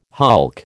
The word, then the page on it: hulk: American English